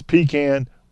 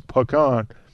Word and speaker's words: pecan: East of the Chattahoochee, it's pecan. West of the Chattahoochee, it's pecan.